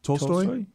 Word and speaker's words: Tolstoy: Is it Tolstoy? – Yeah.